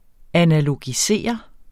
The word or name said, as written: analogisere